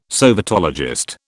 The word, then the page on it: sovietologist: British English